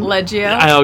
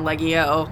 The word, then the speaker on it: Leggio: Leggio. – Okay. I didn't want to pronounce it wrong. – No, thank you. I appreciate that. It's better than Leggio, Lego, Leggio.